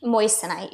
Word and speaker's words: moissanite: Moissanite? – Moissanite, yes.